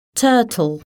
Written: turtle

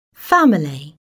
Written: family

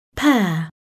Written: pear

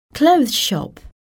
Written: clothes shop

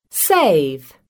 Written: save

